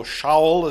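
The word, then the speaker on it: Shaul (שאול): But of course, Saul, or Shaul, as it is more often pronounced in Hebrew,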